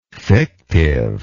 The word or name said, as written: fictiveness